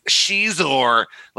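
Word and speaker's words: Xizor: And the guy's like, oh, look at this, Prince Zizor. And this kid goes, it's Shizor.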